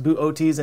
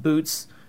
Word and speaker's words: Boötes: And yes, it is pronounced Boötes and not Boots, which is something I'm going to really struggle with in this video.